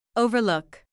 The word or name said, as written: overlook